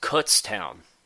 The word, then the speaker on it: Kutztown: Jordan Morgan, my area of the woods, it's pronounced Kutztown, Joe. Kutztown. Not Kutztown. It's Kutztown.